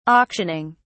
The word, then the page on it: auctioning: British English